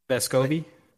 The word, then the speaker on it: Vescovi: I think I said his name right. Vescovi. Vescovi. – There it is, yeah.